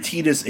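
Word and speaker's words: Tidus: So I think they've changed the pronunciation back to Titus for this. – I'm pretty sure Titus is the official one, but...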